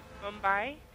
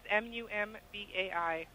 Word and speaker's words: Mumbai: Mumbai. – Could you spell that? – I think it's Mumbai.